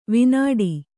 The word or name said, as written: ವಿನಾಡಿ